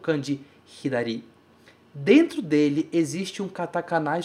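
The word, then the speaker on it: hidari (左): E a pronúncia dele é hidari.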